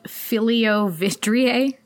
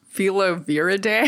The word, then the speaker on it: Filoviridae: And what it is it's a disease caused by the Ebola virus, which is in the family of Filoviridae. Did I say it right? – I say Filoviridae. – Filoviridae. – I think you added an I in there.